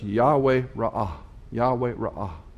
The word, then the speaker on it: Yahweh: Unfortunately, there's no J sound in Hebrew. It's not Jehovah, it's the word Yahweh. We don't know how to pronounce Yahweh. There's no vowel pointing on it. It's Y-H-W-H, roughly transliterated into English.